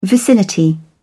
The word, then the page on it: vicinity: British English